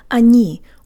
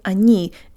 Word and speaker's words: они: The eyes see the O in они, and the brain says, that's an O sound, but it's actually not. Tell your brain that sometimes O's sound like ah's.